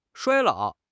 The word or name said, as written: 衰老